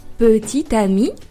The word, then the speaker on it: petit ami: Quand on a trouvé un partenaire, c'est le moment de dire à nos amis que l'on a un petit ami ou que l'on a une petite amie.